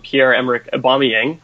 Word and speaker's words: Pierre-Emerick Aubameyang: So when I actually looked at Pierre-Emerick Aubameyang, I hope I'm doing my pronunciation better.